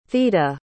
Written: theda

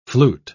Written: flute

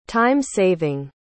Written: time-saving

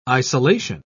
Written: isolation

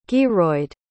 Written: gearoid

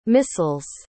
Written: missile's

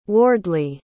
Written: wardley

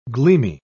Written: gleamy

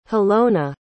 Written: halona

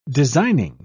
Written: designing

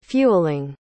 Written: fueling

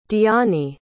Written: Dyani

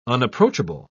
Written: unapproachable

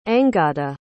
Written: angada

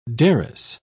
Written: derris

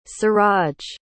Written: siraj

